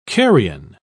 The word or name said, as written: carrion